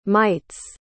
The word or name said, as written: mites